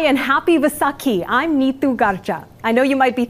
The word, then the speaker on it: Garcha: And the last name is more with a UH than an A. It's Garcha. Garcha. Neetu Garcha.